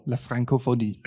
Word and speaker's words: La Francophonie: I think I would prefer to join the La Francophonie. Am I saying that right? – Yeah, you are. La Francophonie, exactly.